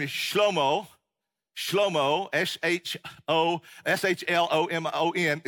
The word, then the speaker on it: Shlomo: I kept calling him Shlomo because that's how you say it in Hebrew, Shlomo.